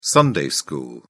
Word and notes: sunday school: British English

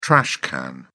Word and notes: trash can: British English

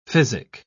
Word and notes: physic: British English

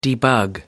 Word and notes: debug: American English

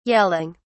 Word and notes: yelling: British English

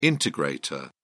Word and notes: integrator: British English

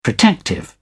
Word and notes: protective: British English

